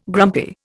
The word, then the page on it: grumpy: Indian